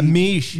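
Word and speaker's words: Miche: Mish or Meesh? It's M-I-C-H-E. – Yeah, Meesh.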